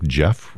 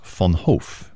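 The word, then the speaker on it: Jef Van Hoof: His name is Jeff, which is spelled like Jeff with just one F. Last name is Von Hoff, V-A-N-H-O-O-F.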